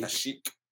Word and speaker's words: Kashyyyk: Kashyyyk. – Kashyyyk.